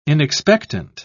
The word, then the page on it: inexpectant: British English